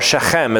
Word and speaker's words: Shechem: But Shechem, or Shechem is how you'd pronounce it.